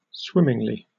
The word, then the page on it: swimmingly: British English